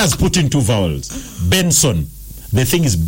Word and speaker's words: BNXN: We are listening to we're listening to a singer from a Nigerian singer, and he spells his name as BNXN. – BNXN. – But do you know what that is pronounced? – No. – Benson.